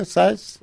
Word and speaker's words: Syce: It's spelled S-Y-C-E. – S-Y-C-E. Okay. – Edgar size. I make a little rhyme out of it. Big size, small size, doesn't matter what size, but I'll rise to the occasion because I serve a big God.